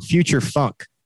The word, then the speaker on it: Future Funk: I said it wrong. Future Funk. Future Funk.